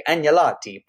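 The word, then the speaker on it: agnolotti: And then the second, which was, more of a appetizer, acorn squash, annulati and prosciutto. Okay. Yeah. I learned with Italian, you don't really pronounce the G, but it's sort of like this nya sound, right?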